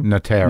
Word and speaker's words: Notaro: Notaro.